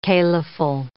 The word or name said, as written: caliphal